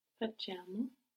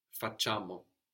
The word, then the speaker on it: facciamo: Facciamo. – Facciamo. So facciamo. You don't really hear the I here, it's just kind of a changing that C to a soft C. Facciamo.